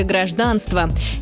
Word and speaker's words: гражданство: Запомните, точно так же ударение падает в слове «гражданство». Это нормативный и единственно правильный вариант.